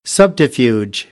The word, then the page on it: subterfuge: American English; ɚ